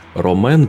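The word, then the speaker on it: Rawmen: Roman пишется как э, рамен, только с W. А R-A-W-M-E-N.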